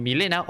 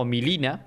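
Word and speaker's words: Mileena: Pero tenemos a la querida ninja rosa de Milena o Milina, para los que un poco más eh, fans de la pronunciación buena en inglés.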